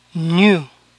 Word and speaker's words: new: En cambio, nuevo se pronuncia new, new. Eso significa que para estas palabras la EW final suena U.